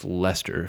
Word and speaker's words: Leicester: It's pronounced Leicester, if I remember my football correctly. – Leicester, yes.